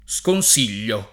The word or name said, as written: sconsigliare